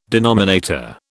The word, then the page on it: denominator: British English